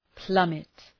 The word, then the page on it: plummet: ‘plʌmıt